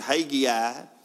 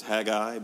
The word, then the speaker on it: Haggai: It's pronounced a million different ways depending on where you're from. If you're from the South, most people pronounce it Haggai, but I think the correct phonetical pronunciation is Haggai, but for those of us who understand classical country music, his friends called him the Hag.